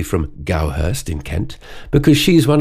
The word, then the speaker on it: Goudhurst: So I thought, is it Goudhurst or Goudhurst? So I looked it up, and it's Gowhurst, with D in the middle of the name, silent.